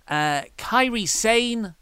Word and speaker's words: Kairi Sane: Kairi Sane. I said that right, didn't I? – Yes. – Yes.